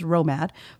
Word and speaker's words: ROMAD: I was a radio operator, maintainer, and driver, or ROMAD, pronounced ROMAD for short.